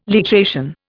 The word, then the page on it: literation: Indian